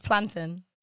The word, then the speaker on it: plantain: Isn't it A-I-N? – Yeah, but it's plantain. – Exactly. – Right. – Where's the A gone? – It doesn't exist. It's gone. – It's a silent A. – It's a silent A in Jamaica.